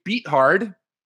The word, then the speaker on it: Beathard: Second, we have CJ Beathard. How you correctly pronounce his name, because he's about to get beat hard by Jimmy Garoppolo.